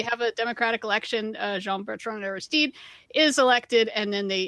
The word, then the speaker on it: Aristide: Haiti ends a three decades of military rule with the election of Jean Bertrand Aristide. Am I saying that right? – Aristide.